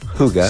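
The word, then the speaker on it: hygge: Que se llama hookah. – Huga, sí, se escribe como hige, ¿no?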